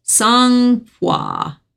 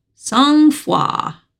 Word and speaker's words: sang-froid: Well, I'm going to do my word of the day, which is a French word pronounced sang-froid. Sang-froid.